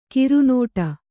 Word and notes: ಕರ್ನಾಟ: karnāṭa; Kannada

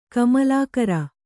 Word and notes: ಕಮಲಾಕರ: Kannada

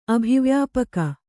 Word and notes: ಅಭಿವ್ಯಾಪಕ: abhivyāpaka

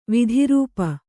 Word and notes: ವಿದ್ರೂಪ: vidrūpa

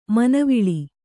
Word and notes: ಮುನಿವಾಳು: munivāḷu

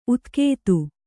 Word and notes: ಉತ್ಖಾತ: Kannada

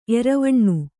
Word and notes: ಎರವಣ್ಣು: Kannada

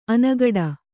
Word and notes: ಅನುಗೂಡು: anugūḍu

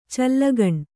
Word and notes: ಚಲ್ಲಗಣ್: kannada

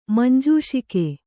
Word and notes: ಮಂಜೂಷಿಕೆ: kannada

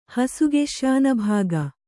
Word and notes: ಹಸುಗೆಶ್ಯಾನಭಾಗ: kannada

